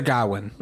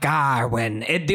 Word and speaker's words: Gawain: Sir Gawain. – Garwin? – Because it's not Garwin. The only person who says British. Garwin is fucking my guy, my King fucking Arthur.